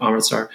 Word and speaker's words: Amritsar: So the through line I want to follow is talking about the massacre at Amritsar. – Oh, right. – Am I saying that right? – Yeah. – Yeah. – Okay. Talking at Amritsar.